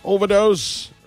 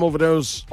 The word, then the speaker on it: Ovadoze: Overdose remains my name. Of course, you can catch me on socials. I am Overdose on Instagram. The Overdose is spelled O-V-A-D-O-Z-E.